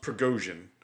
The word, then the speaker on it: Prigozhin: So I believe his name is pronounced Prigozhin. – Yes.